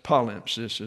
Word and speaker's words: palimpsest: Fortunately, we can excavate the truths that lie buried under the progressive palimpsest in order to, and that's palimpsest is the correct pronunciation, progressive palimpsest in order to save our history and the end ourselves.